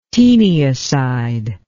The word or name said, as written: teniacide